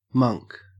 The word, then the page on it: monk: Australian English